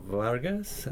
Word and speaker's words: Vargas: Yeah, my name is Alberto Vargas, V as in Victory, A-R-G-A-S.